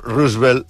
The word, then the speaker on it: Roosevelt: Bueno, de fet, pronuncies Roosevelt. – Es diu Roosevelt, eh? – Perquè era d'origen holandès, ell deia que es deia Roosevelt.